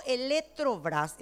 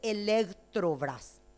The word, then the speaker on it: Eletrobras: Y cuando digo Eletrobras, estoy diciendo bien, porque no se dice Electrobras. Es en portugués y es Eletrobras el nombre.